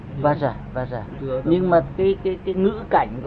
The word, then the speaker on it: Vajra: À, người Tây Tạng họ đọc là Benza. Benza tức là Vajra hay Vajra được.